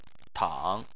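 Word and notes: tang: tone 3

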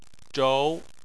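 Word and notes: zhou: tone 2

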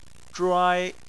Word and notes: zhuai: 1

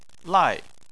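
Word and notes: lai: tone 4

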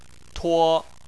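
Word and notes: tuo: tone 1

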